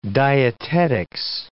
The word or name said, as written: dietetics